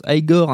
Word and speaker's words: Igor: par son assistant évidemment bossu Igor, qui se prononce Igor,